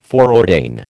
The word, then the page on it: foreordain: American English